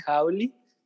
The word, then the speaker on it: Jawli: Pues que nos sigan en todas nuestras redes, en Instagram como arroba a veces Jauli. Jauli se escribe J-A-W-L-I.